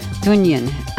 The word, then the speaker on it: Dungen: Dunyin, and that's spelled D-U-N-G-E-N, and their name means a grove of trees in a clearing, or in a forest clearing.